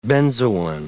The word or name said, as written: benzoin